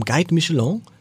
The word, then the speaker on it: Guide Michelin: Und der, um den es jetzt gleich gehen wird, der hat vom Guide Michelin, richtig ausgesprochen, als einer der ersten überhaupt einen Stern erhalten, und zwar einen grünen Stern.